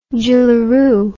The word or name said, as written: jillaroo